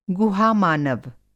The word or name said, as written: गुहा-मानव